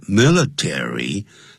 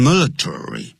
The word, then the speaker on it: military: military, 很多人念成 military, 叫 military code, 就是密码。